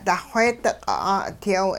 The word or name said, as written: duxweedi-q' 'aa'udyaw